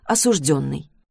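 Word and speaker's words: осуждённый: «Осужденный» — нормальное слово, если нормально ставить ударение на «ё», если нет, то это признак работника системы.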